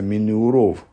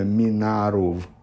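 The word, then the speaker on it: минуров (מנעריו): вот «минуров», читается «минуров», а написано как будто «минаров».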